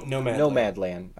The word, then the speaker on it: Nomadland: I really do want to do, I think that movie, what's like No Man's Land, or am I always saying that wrong? I feel like... – Oh, no, Nomadland.